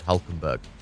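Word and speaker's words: Hülkenberg: That's what I hear whenever I hear the word Hulkenberg. – Hulkenberg! – And same, similar syllables, isn't it? – Yeah.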